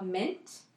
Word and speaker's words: Mynt: It's called Mint. It's spelled M-Y-N-T.